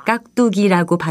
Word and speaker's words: 깍두기: 깍두기의 첫 음절의 받침은 기억이고 두 번째 음절의 첫 소리는 디귿입니다. 이것을 깍두기라고 발음하기 때문에 특히 두 번째 음절을 쌍디귿으로 혼동하기 쉬우니까요.